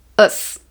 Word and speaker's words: -ous: E hoje eu vou ensinar para vocês como pronunciar corretamente palavras que têm o final O-U-S. Vamos lá? O som desse sufixo não é ous, e sim us.